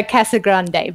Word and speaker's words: Casagrande: Well, I'm going to make you feel slightly better because I don't even say it correctly myself. I am very Aussie in the way that I say it. Essentially, it's Elisha Casagrande. You can roll the tongue, but please don't.